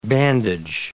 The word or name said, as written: bandage